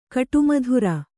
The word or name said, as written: ಕಾಡುಮದರು